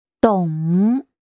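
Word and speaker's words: dong (懂): The word for understand, to understand something is dong. Dong, red V for victory. Dong, for I understand. – In pinyin, it's spelled D-O-N-G.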